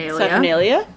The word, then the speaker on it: Saturnalia: Saturnalia? – Yep.